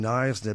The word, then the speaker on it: Knysna: It's from the Khoi, N-A-I-Z-A, which is probably why we don't pronounce the K in Knysna, because the original pronunciation starts with N, was Knysna.